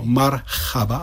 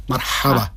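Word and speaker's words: marhaba (مرحبا): Taky není to marhaba, ale ha, marhaba.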